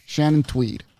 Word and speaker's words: Shannon Tweed: Shannon Tweed.